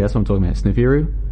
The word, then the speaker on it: Sneferu: And so Sneferu, that's what I'm calling it, but it's spelled S-N-E-F-E-R-U, Sneferu, Sneferu.